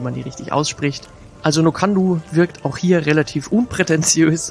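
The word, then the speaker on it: Nc'nean: Ich habe es hier und da mal ein bisschen komisch gehört, dass Leute das aussprechen wie No can do, so nach dem Motto, darfst du nicht, lustig, klingt so. Ist aber eigentlich Quatsch, weil die Brennerei überhaupt gar nicht so ausgesprochen wird, sondern die wird ganz einfach Nokandu ausgesprochen.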